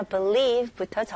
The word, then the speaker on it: believe: 일단 첫 번째 단어 believe부터 정리해 드릴게요. 네, believe 동사로 믿다이죠. 자, 발음 연습해 볼게요. 여기서 스펠링 보시면 이게 be이지만 발음은 be보다는 be, e, i 발음으로 한번 해 보세요. be 그다음에 두 번째 음절에는 leave, e 발음으로 believe, believe, believe, believe.